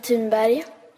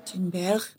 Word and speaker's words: Thunberg: Thunberg? – My name is Greta Thunberg. I'm 15 years old and I'm from Sweden. – Thunberg.